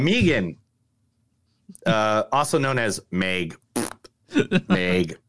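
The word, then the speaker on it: M3GAN: Megan? How do you pronounce it? – It's Megan, but I'm calling it Mithrigan because that's what it says.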